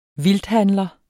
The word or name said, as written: vildthandler